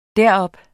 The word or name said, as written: derop